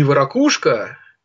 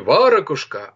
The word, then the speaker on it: варакушка: Кстати, не ворокушка, не варакушка, а именно варакушка. Вот запомните, как ставится ударение.